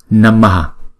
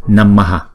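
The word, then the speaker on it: namaḥ: Visarga, chữ H có dấu nặng, là nguyên tắc là chúng ta lấy chữ nguyên âm ngoài trước, chúng ta choàng ra ngoài sau, chúng ta đọc là Namaha.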